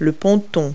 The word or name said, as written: dock